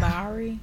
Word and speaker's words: Mowry: Mari? I can never say their last name right. I apologize. – Tia and Tamara. – Yeah, they're 48. – Mari? – Yeah. M-O-W-R-Y. – I feel like it's Mari.